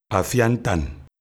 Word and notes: Afia ntan: LHHL

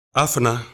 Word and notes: afuna: Efik

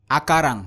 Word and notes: akaran: Efik

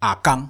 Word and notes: akaangg: Efik

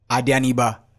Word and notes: adianiba: Efik

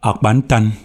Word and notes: Akpantan: LLHM

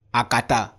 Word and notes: akata: Efik

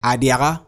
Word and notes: adiaha: Efik

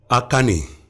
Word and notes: akani: Efik

